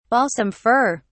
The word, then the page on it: balsam fir: British English